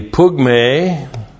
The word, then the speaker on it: pugme: You had the pale, P-A-L, long E, pale, were the wrestling events. You had the pugme, P-U-G-M, long E, whoops. No.